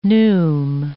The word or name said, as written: neume